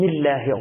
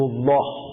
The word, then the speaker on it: الله: مثلا شما الله تو عربی چجوری تلفظ میکنید؟ میگه بستگی داره قبلش چی بیاد. اگه قبلش ا بیاد ما الله با صفت استفال تلفظ میکنیم، یعنی میاریم پایین میگیم بسم الله الرحمن الرحیم بسم الله، اما قبلش او باشه میگیم رسول الله. اگه بگی رسول الله خنده دار میشه اصلا، دست خود میفهمی چرا مسخره شد.